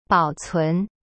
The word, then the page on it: 保存: bao cun